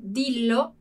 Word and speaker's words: dillo: Ali com o guarda-me, a gente não precisou raddoppiare, né? Duplicar a consoante, d'accordo? Então, dillo, dillo.